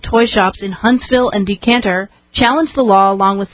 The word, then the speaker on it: Decatur: You got to get the correct southern pronunciation. It's Decatur, not Decatur.